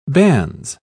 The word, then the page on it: bans: British English